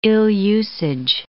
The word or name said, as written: ill-usage